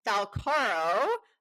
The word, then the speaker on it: Falcaro: My brain wasn't working yesterday when we were recording our intro, and Nicole Falcaro, which is the correct pronunciation of her last name, I've come to discover, even though she doesn't mind either.